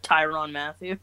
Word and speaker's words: Tyrann Mathieu: Yeah, I think not only will the offense really get on them, but I think the Honey Badger, Tyrann Mathieu, I think that's how you pronounce his name, on the Chiefs. – Tyrann Mathieu.